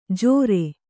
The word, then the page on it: ಜೋರೆ: Kannada